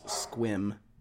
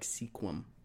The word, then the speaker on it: Sequim: The one that gets everyone is Squim. – Yeah. – Squim is probably the one, if you get that one right, people know you're from the Northwest.